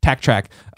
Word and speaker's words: TakTrack: It's Tack Track's single here, Out for Justice. Tack Track is spelled TAKTRACK. So just TAK is the weird part about that. Tack Track.